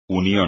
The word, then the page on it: unión: espagnol